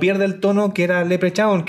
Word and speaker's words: Leprechaun: Eh, bueno, se pronuncia así, pero yo nunca lo he dicho así, nunca he dicho crown sino que es Leprechaun,